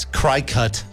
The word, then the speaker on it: Cricut: C-R-I-C-U-K. – It's Cricut. – C-R-I-C-U-K. – C-U-T.